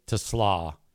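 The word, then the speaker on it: Isaac TeSlaa: Isaac Tesla is officially how you pronounce that guy's name. It's spelled T-E-S-L-A-A, capital T, lowercase E, capital S-L-A-A.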